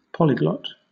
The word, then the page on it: polyglot: British English